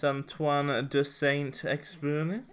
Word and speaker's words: Saint-Exupéry: Oh, yes, Antoine de Saint-Exupéry.